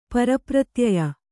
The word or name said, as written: ಪರಪ್ರತ್ಯಯ